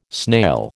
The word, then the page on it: snail: American English